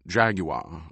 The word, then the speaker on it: Jaguar: He'd already bought a Jaguar, pronounced Jaguar over here, you'd have to remember, but both times he'd walked to it at the dealership, he'd gone to the left front door instead of the right.